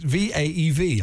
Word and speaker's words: Vaev: It's V A E V. I'm pronouncing it Vive. V A V E. Sorry, it's Vive. V A E V. Oh, Vaev. it's still Vaev. it's still Vaev. Vaev tissue. Vaev tissue.